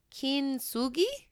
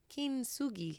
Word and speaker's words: kintsugi: I think that's how we pronounce it. We watched a pronunciation video. Kintsugi. No, she said like king. Yeah, that's what she said. Okay. – Kin. – Yeah. – Kintsugi. – Kintsugi. Okay.